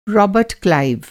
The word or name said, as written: रॉबर्ट क्लाइव